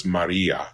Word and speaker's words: Maria: Anyway, Maria was originally had a short I, but in ecclesiastical Latin, it came to be pronounced long, probably because the word that we'll get to later could mean, you know, the sea. Like, you know, the Mediterranean. It's in ecclesiastical Latin, it has a long I. So it's Maria. So the accent is on that penultimate syllable rather than on the first syllable.